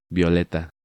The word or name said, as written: Violeta